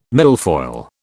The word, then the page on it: milfoil: British English